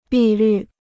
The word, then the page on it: 碧绿: bì lǜ